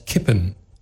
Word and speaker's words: kippen: Kiepen is ontleend aan het Duitse woord dat uitgesproken wordt als kippen. Zo'n i-klank waar het Duits gewoon een e heeft, is typisch voor de Nederlandse uitspraak van het Duits.